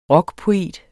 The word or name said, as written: rockpoet